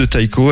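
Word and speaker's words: Tycho: Taiko dans Novorama, ou Ticho hein, si vous voulez le prononcer à la française, avec son album qui s'appelle Simulcast, Clément.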